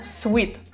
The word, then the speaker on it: suite: Кстати, заметили, наверное, произносится прям suite. Почти как sweet, сладкий.